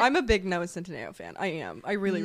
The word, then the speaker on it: Centineo: Centineo. – I say it wrong every time. Noah Centineo.